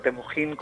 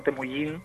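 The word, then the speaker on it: Temüjin: tanto Temujin como Temujín, podrían ser, podrían ser válidas. De hecho, la pronunciación exacta del mongol medieval como del chino medieval no se, no se conoce al 100%, o sea que yo creo que se podría decir de, las dos maneras.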